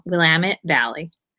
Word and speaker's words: Willamette: Is food and drink editor at the Statesman Journal and a freelance recipe developer in Oregon's beautiful Willamette Valley. Thanks for joining me. Did I say it right that time? – You did. Willamette Valley. Yeah, they have t-shirts that say, it's Willamette, damn it.